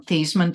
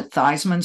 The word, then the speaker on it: Theismann: Notre Dame's PR guy said, hey, how about you change the pronunciation of your name from Theismann to Theismann so that it rhymes with Heisman and you might have a better chance?